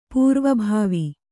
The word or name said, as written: ಪೂರ್ವಭಾವಿ